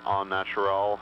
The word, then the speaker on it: Au Naturel: It is French spelling and pronunciation, Au Naturel Health Solutions.